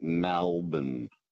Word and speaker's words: Melbourne: The other night on overnights with Ashton, someone rang up and said that the way you pronounce Melbourne is it rhymes with Falcon, and it's like Melbourne.